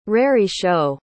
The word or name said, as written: raree-show